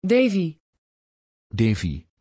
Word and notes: devi: Dutch

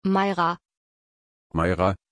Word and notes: Maira: German

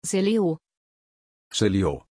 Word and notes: celio: German

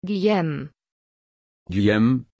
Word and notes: guillem: German

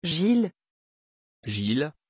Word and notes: Gill: French

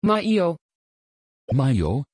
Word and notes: Mailo: Dutch

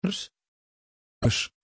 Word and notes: Rhys: Dutch

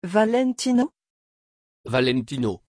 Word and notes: valentino: French

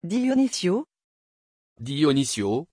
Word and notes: Dionisio: French

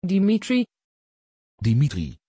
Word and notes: Dimitri: Dutch

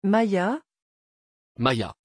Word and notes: Maya: French

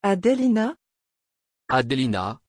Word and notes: adelina: French

